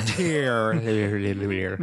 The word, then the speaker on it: Tearle: Directed by Steve Tearle, T-E-A-R-L-E. Any guesses at how you pronounce that one? Tearle? – I think you nailed it, Tearle?